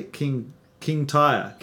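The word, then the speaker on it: Kintyre: How do we say this? King Grey? King Tyre.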